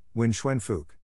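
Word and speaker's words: Nguyễn Phúc: A national security official replied, Nguyen Phuc. Rhymes with book.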